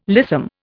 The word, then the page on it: lissom: Indian